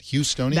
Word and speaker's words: Houstonians: Houstonians, that's how you say it? Houstonians? – That's right.